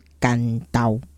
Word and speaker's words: 关渡: 关都、其实这个所在啊，叫它的古宅，要读法就是干刀。 – 干刀，对，那因为云长就是关公本人啊，关云长，所以。关先生渡海，过海，所以是过关渡。那国语汉字是写关渡没有错，但是当地的古早名字的发音其实是干刀。